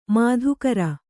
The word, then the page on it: ಮಾಧುಕರಿ: mādhukari